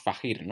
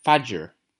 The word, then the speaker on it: Fajr: Y me hizo gracia porque estaban comentando sobre el nombre de a quien llamamos Fajir, ¿no? ¿Sabes cómo se pronuncia ese nombre? – Yo me lo voy a jugar con Far. – Pues se pronuncia Fajr.